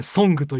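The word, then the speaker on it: 村歌: 村に歌と書いて「ソング」という歌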